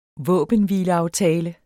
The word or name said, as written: våbenhvileaftale